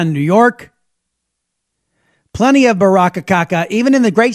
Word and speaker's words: Baraka: A guy named Roz Baraka rhymes with caca.